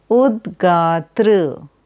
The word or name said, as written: Udgatri